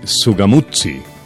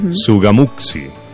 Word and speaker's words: Sugamuxí: Si me lo permites, Angie Valeria y amigos, si me lo permiten, me gustaría explicar un poquitico de la provincia de Sugamuxí. Eso se escribe con X con al X. Final. Sugamuxí. Sugamuxí. Por eso al final es como difícil de pronunciar.